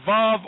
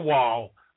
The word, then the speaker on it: Vav: Or Jehovah, because Yahweh, Vav is pronounced Vav or Wal, and so it just depends on, you know, who's saying it.